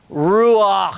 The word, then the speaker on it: ruach: It is the Hebrew word ruach. And you can't say it in good Hebrew without a little something, you know, coming out your mouth as you say.